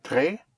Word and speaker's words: très: Je suis très occupé maintenant. How you spell très? – T-R-E-S with an accent over the E. Très, yes. So here you have an S, silent S, followed by a vowel.